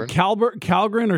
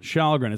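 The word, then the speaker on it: Källgren: Is it Calgren or Shalgren? Shalgren. It's kind of like the Shillington treatment. Yeah. It's the K for the SH. Yeah. – Okay. Shalgren, he might be the solution.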